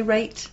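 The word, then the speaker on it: Rait: Do you say Raitt? – Raitt. – And team. That's R-A-I-T.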